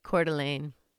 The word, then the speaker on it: Coeur d'Alene: And that brings us to September 9th, 2001, where several phone calls start coming in to the Coeur d'Alene. Is that how you say it? – Coeur d'Alene.